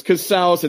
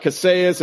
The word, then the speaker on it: Casals: It's Casals. It's not Casas. It's not Cassius. It's not not Martinez. – Rhymes with house, like you're in the house of Casals, y'all. That's what. House of Casals. It rhymes with house.